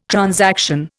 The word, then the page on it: transaction: Indian